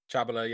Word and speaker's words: Chabala: In addition to Harry Wilson, Paulo Gazzaniga, and Rodrigo Muniz, the two Watford lads, Nathaniel Chabala, is that how you say his name? Chabala? Chabala, yeah. Chabala.